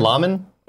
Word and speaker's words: Luhrmann: Was it Baz Luhrmann? – Lahman? Baz? – Oh, it's Luhrmann. I know that's how you say his name, but I don't know if that's him as the director.